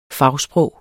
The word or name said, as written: fagsprog